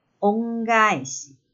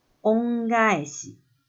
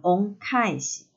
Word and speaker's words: 恩返し: 这里有一个发音上的变化，要特别留意，那就是“ 返し” 报答这个字，因为这个字的前面多了“ 恩” 恩惠这个字，所以“ 返し” 的第一个字的发音要从清音的“ 卡” 变成了浊音的“ 嘎”，所以正确发音是“ 恩返し”，“ 恩返し”，“ 恩返し”，而不是念成“ 恩返し”。这个发音上的变化要特别留意一下。